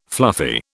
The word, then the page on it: fluffy: British English